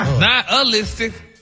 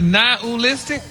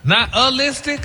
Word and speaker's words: nihilistic: Nihilistic? – Wow. – Stop putting the ooh in there. – Nihilistic? – There you go.